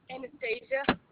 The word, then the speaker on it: Anastassia: Anastasia. – Spell that, please. – A-N-A-S-T-A-S-S-I-A.